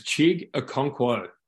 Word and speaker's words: Chig Okonkwo: His name's Chig Okonkwo.